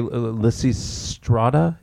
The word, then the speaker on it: Lysistrata: It's an adaptation of the Greek play Lysistrata.